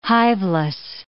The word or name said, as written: hiveless